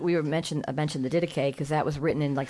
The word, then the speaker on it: Didache: the Didache. How do you say it? The Didache. Yes.